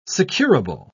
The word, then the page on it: securable: British English